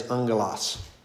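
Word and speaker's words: ungulos (ἄγγελος): When you put two gammas next to each other, then it's like an NG. So like angel, right, is ungulos. Yeah, because it's actually alpha, two gammas right in a row. And you're not, when there's two gammas, it's actually pronounced with a NG sound. So it's ungulos.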